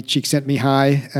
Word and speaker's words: Csikszentmihalyi: So, you know, flow with Mihaly Csikszentmihalyi, and kudos to both of us for knowing how to pronounce his name correctly.